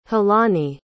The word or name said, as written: khelani